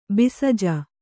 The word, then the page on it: ಬಿಸಜ: bisja